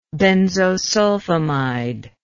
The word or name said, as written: benzosulfimide